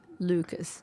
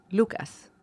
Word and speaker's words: Lucas: Because in English, they're like Lucas. And in Spanish, it's Lucas.